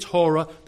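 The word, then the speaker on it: hora (ὥρα): And hora, if you spell it in English, is H-O-R-A. And it sounds like, yep, hour.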